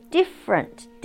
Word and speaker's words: different: Different, different, different 是 different, different, 它的发音一共有三段，高音在第一段 different, 连在一起就是 different floor.